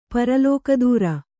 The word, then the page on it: ಪರಲೋಕದೂರ: paralōka dūra